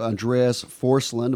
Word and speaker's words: Andreas Forsland: This is the CEO we're speaking to, Andreas Forsland.